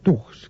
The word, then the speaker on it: thug: Se llaman los Tugs, que se escribe T-H-U-G.